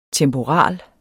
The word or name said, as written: temporal